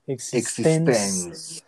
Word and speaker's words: Existence: Sí, bueno, pues Existence eh, he investigado que se, que se, pronuncia como con acento en la E al final. – Existence.